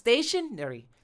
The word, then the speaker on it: stationary: stationary stationary stationary stress ที่คำว่า stay